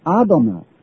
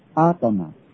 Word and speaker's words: Adonai: 它原来首的读音是用 Adonai Adonai，意思就是万有的主宰，万有的主宰是创造万有，而且万有是属于他的，他有权柄能力来管理受造物，这叫做 YHWH。